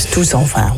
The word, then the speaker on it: Tout s'en va: Spreek je die T dan wel uit, of ga je dan meteen over op die S? – Je gaat meteen over op de S. Tout s'en va.